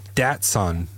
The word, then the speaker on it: Datsun: The result was a 495cc compact car called the Type 1, which was launched under the name Datsun, as in the son of Dat, and it's spelled S-O-N, not S-U-N. – S-U-N.